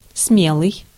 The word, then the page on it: brave: American English; [ˈbreɪv]